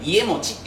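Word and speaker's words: 家茂: 家重って書いて家持ちって読むけど、家重はもう 9代目にいるから読み方は家持ちです。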